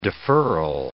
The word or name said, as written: deferral